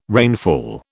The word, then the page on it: rainfall: British English